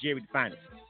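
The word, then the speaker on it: Gerid'Fyniz: Jerry Finest, spelled G-E-R-I-D, apostrophe F-Y-N-I-Z. But it's pronounced Jerry the Finest, so you know.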